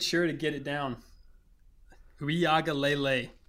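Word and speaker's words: Uiagalelei: Hey, I'm saying it right. It's Uiaga Lele.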